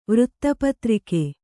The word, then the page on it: ವೃತ್ತಪತ್ರಿಕೆ: vřtta patrike